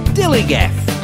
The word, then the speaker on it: DILLIGAF: And I can relate to their attitude too, in a word, dillygaff. That's spelled D-I-L-L-I-G-A-F, dillygaff.